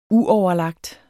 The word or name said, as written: uoverlagt